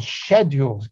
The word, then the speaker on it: schedule: And one of those that came to be known actually only in the close of the colonial period was the so-called scheduled castes. The schedule, that's the British pronunciation of schedule,